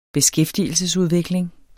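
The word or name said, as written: beskæftigelsesudvikling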